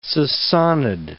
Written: Sassanid

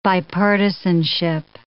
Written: bipartisanship

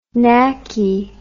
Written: knacky

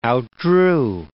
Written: outdrew

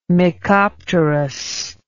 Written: mecopterous

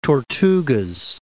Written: Dry Tortugas

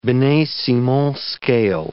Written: Binet-Simon scale

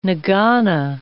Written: nagana

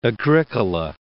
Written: Agricola

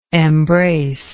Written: embracer

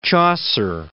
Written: Chaucer

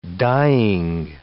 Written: dying

